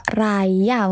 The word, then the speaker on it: Raja: Misalnya nih ya, di awal cerita ada Pak Raja, uh, Pak Raya mungkin ya. Itu pemilik kretek jagat raya tulisannya itu kretek jagat raja tapi bacanya itu raya